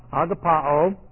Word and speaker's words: agapao: And agapao, it's a long O.